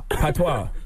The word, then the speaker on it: patois: Patois. – The S is silent.